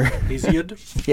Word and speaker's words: Hesiod: Hesiod.